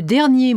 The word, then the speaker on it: dernier: The ER in premier and dernier has two different pronunciations. E, as in E, when it ends a sentence or is followed by a word starting with a consonant, and R, as in mer, when it precedes a word beginning with a vowel sound.